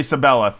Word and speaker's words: Sabella: Nathan Lane and Ernie, what's his face, fucking Saville, is that how you pronounce it? I'm not really sure. Ernie Sabella, there we go,